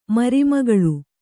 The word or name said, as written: ಮರಿಮಗಳು